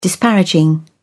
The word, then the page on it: disparaging: British English